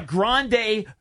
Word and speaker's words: Grande: I don't know if it's Grande. I get confused. – Well, you've heard it. – Grande still has an incredulous look on his face. – You've heard it pronounced Grande a billion times.